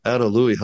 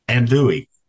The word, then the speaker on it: andouille: Adelui. Hush. Okay. Adelui. How do you say that? – Andouille.